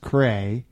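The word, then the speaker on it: Kra: Well, it's K R A, pronounced cray, and there is some sort of line above the. – Yes, it's a yeah. long, long A.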